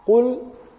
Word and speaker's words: قل: So it shouldn't be Kul because it will change the meaning,